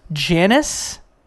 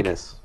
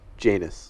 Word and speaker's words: Janus: But you have to kill Janus. I did it again. – Janus. – How do I say it? – Janus.